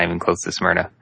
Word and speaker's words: Smyrna: Tell us how awesome it is to live in Smirnoff or Smyrna? – Smyrna. – S-M-Y till I die. That's what the locals say.